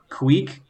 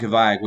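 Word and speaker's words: kveik: I think you pronounce it technically. It's Scandinavian, like Kweek, but it's Kvieik. – Okay. – And I pronounce it Kvayak, which I know the Scandinavian people are probably cringing if they're listening to this.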